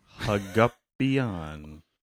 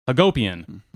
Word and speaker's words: Hagopian: That would be the Armenian pronunciation, or as close as I can manage. If we said it like an American, it would be Hagopian. But Hagup, H-A-G-O-P, the Armenian name version of Jacob.